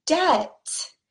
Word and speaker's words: debt: And same for the next word debt. Debt, D E B T. Again, B is silent. We do not say debt debt. We say debt, debt. So the B is totally silent.